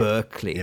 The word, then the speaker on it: Berkeley: Berkeley.